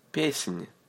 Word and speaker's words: Песнь: Say ny, ny. Песнь. Soft sign on the end of letter N.